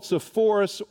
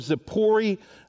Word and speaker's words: Zippori: Well, we know that the closest city to Nazareth, where he grew up, was a place called Sepphoris or Zippori, uh, would be the Hebrew way of pronouncing that.